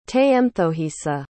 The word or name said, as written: teyemthohisa